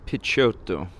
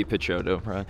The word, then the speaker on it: Picciotto: Guy Picciotto. He doesn't say it like that. He says Guy Picciotto.